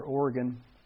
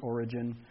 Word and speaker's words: Origen: Oregon, the theologian. I think you pronounce it Oregon, Origen.